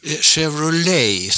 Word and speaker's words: Chevrolet: Det heter Chevrolet, Chevrolet, Chevrolet och inget annat. Men fortfarande så säger jag kanske Chevrolet. Jag är lite för svenska där, va. – Kör du själv en Chevrolet hit ut när du pendlar från stan? – Nej, det är bara Saab som gäller idag.